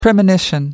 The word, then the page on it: premonition: American English